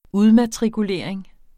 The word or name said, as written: udmatrikulering